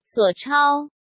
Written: Suo Chao

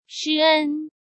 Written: Shi En

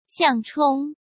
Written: Xiang Chong

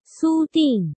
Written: Su Ding

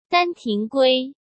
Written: Shan Tinggui

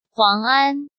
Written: Huang An

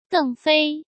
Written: Deng Fei

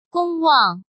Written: Gong Wang

